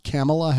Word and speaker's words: Kamala: That's actually how she wants it pronounced, Kamala Harris.